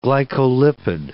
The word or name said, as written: glycolipid